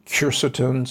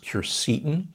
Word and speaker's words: quercetin: I'm a big fan of quercetin, sometimes pronounced quercetin.